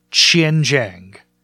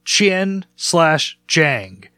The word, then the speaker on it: Qianjiang: It looks totally phonetic. It is not. It is Qianjiang. Qian/Jiang. This is a place in China. This is non-disputable. It's Qianjiang. It's not a matter of like, well, you pronounce it from where you're from or no, this is a thing.